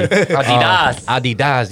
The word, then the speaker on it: Adidas: Yeah, like so basically they were just performing the song, and obviously Adidas, how they say it in Germany. – Adidas, you know.